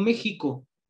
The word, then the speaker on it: México: Pero digo México con J y lleva X.